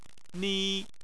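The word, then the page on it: ni: tone 1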